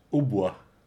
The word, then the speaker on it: hautbois: Hautbois. – Oh, that's how it's pronounced.